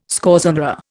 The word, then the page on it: scorzonera: Indian